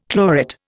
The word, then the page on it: chlorate: Indian